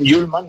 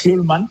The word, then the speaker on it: Hjulmand: Morten Hjulmand, le han llamado en la presentación Hjulmand. Nosotros decíamos más Hjulmand. – Sí. – Pero bueno, vamos a fiarnos de esa pronunciación para dar la bienvenida a un centrocampista nuevo que firma, como digo, 5 temporadas en el Atlético de Madrid.